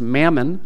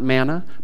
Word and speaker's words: mammon: Now this word that gets translated money comes from an Aramaic word that is pronounced mammon, not manna, but mammon.